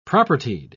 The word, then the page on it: propertied: British English